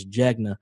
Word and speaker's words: Jegna: They say Jenga like the board game, but it's Jegna.